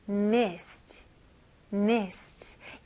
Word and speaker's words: mist: 네, mist는 발음은 mist. 오케이, 여기서 i 발음입니다. 우리나라 사람들은 i 발음 좀 어려워해요. 일단 우리나라 발음이 없으니까. 그래서 이거는 e 하고 e 하고 사이인데요. 입 모양을 잘 보시면서 e, e 여기 입꼬리가 좀 길어지죠? e, mist, mist.